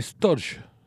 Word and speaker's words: Storge: El Storage, eh, no, Storge, ¿sí? que se escribe S-T-O-R-G-E, que es el amor amistoso y leal, maduro y comprometido con las relaciones duraderas.